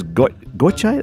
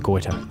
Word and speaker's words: Gotye: All right, here's Gotye. That's how you're saying it. What are some of the more unusual pronunciations? – Yeah, Gotye. Gotye. Gotye. – Gotye. – But, actually, no, I've got to say, by far and away, the worst variation is Goiter.